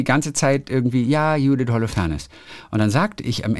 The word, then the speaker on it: Holofernes: und du hast immer gesagt Holofernes und ich habe die ganze Zeit ist gedacht, falsch.